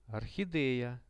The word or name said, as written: orchad